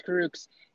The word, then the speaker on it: Kurucs: Kurucs.